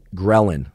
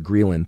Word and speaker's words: ghrelin: you release a hormone in your body called ghrelin, sometimes actually called ghrelin. Thanks for all of you ghrelinistas or ghrelinistas that corrected my pronunciation. It's both ghrelin or ghrelin. Either one works.